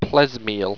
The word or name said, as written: plesmeal